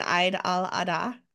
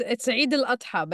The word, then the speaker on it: Eid al-Adha: because WordCamp Europe next year falls again on Eid al-Adha. And please correct me, if I'm saying that wrong. – It's Eid al-Adha, but it's okay.